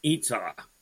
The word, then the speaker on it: Ytsau: Y-T-S-A-U. Pronounced Itza.